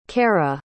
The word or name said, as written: kerra